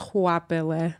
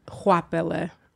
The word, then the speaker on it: Goapele: And it's really pronounced Goapele. – Say it again. – Goapele. – Goapele. – You did excellent. But I would say since I was really little, it's been mispronounced in like 1,000 different ways. It's a South African name.